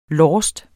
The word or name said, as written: lost